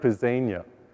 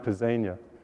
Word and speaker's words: Pisania: It's Cedric Pisania. My last name sounds like something you might like to eat, lasagna. Lasagna Pisania.